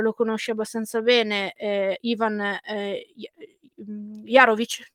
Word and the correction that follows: Javorcic: giusto? se pronuncia in questo modo. – Sì, sì, Javorcic, il mister lo Javorcic.